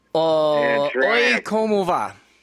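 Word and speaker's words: Oye Como Va: Okama Vega, how do you say that? Vega? – Oi, como va? – Okay, the Triumph did fight the good fight.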